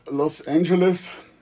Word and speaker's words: Los Angeles: Los Angeles wird nur mit Ä ausgesprochen, also man kann sich als Gedächtnisstütze im deutschen Anfangslaut von Äpfel als Hilfe nehmen. Eine andere Aussprache ist eigentlich da immer falsch.